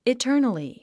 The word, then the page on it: eternally: itə́ːrnəli; イターナリィ